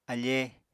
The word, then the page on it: afee: Apatani